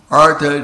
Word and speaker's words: artha: In the Bhagavad Gita, Krishna says, Chatura vidā vidante mam janasukritina arjuna artha jignasya artharati jnani charbharatara shiva. So different people have different desires. Some people are artists. A with a long A, artha.